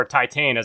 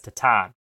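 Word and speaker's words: Titane: Third, I apologize for all the stereotypical French jokes I made while we were discussing Tatan, or Titan, as I said in the last episode, which apparently is supposed to be pronounced as Tatan.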